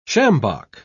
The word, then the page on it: sjambok: British English